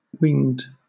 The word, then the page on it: whinged: British English